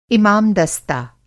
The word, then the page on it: इमामदस्ता: Hindi